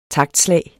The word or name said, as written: taktslag